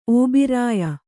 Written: ಓಬೀರಾಯ

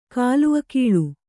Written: ಕಲ್ಲಿಗೂಳು

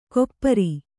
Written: ಕೊಪ್ಪಾರಿ

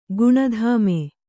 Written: ಗುಣಧಾಮೆ

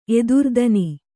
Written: ಎದುರ್ದನಿ

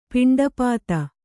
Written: ಪಿಂಡಪಾತ

